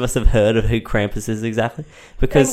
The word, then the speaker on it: Krampus: I could say it like what I've heard actual German people say it like. – Say it. – Krampus. – Krampus. – Or I could just say Krampus.